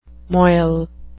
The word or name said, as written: moil